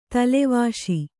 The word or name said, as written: ತಲೆವಾಶಿ